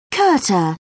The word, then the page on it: kurta: British English